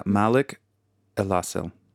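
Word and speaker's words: Malik Elassal: Yeah, let's get his name right for the people here. Yeah, Malik Elasal. – Yeah. – M-A-L-I-K. And last name is E-L-A-S-S-A-L.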